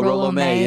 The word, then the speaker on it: Rollo May: Oh, Rollo May.